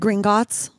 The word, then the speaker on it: Gringotts: Gringotts.